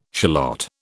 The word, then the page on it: shallot: British English